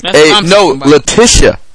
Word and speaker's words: Latisha: Jerry Rice proposes to his girlfriend, Latasha Peyoy. I'm butchering that. – Get you, every man should get them Latasha. – Latisha. Latisha with an I.